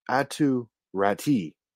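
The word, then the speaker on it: Aatu Räty: Bo Horvat, formerly of the Vancouver Canucks, was traded to the New York Islanders in exchange for Anthony Beauvillier, Atu Ratti. I think it's actually the pronunciation to his name is like Atu. It's like Atu Ratu.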